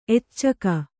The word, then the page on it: ಎಚ್ಚಕ: kannada